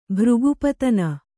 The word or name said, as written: ಭೃಗುಪತನ